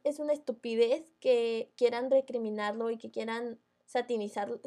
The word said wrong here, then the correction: satanizarlo: ah, ¿Cómo se dice? Satanizarlo. Satanizarlo.